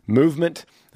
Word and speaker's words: MVMT: Movement, and that's spelled out MVMT, is how they call it, MVMT, grew into the fastest growing watch brand, shipping to over 160 companies across the globe.